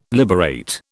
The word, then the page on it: liberate: British English